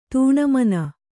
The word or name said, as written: ತನಮನಿ